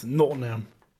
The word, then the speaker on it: Nottingham: You're not saying it right. It's Nottingham. – Nottingham. – Yeah, that's it.